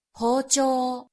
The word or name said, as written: hocho